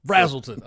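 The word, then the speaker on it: Braselton: How do we say it wrong? Brazelton or Brazelton? Brazelton. Okay.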